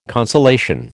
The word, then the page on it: consolation: American English